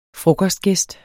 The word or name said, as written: frokostgæst